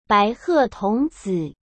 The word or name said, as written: baihetongzi